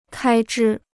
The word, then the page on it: 开支: kāi zhī